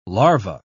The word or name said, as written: larva